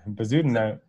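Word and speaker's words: Bezuidenhout: You got to try it. – Bezudenhout? – Yeah, pretty good. Bezudenhout, yeah.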